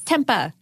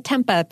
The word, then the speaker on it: tempeh: It's Tempeh. I've always said tempeh because that's what it looks like to me, but apparently it's pronounced tempeh.